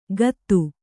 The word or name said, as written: ಕತ್ತು